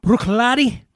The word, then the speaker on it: Bruichladdich: This is Bruichladdich. Bruichladdich is how you say it. You spell it Bruichladdich.